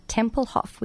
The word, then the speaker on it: Tempelhof: Ellie and Andy are from the band Huntley, whose song Tempelhof we just played.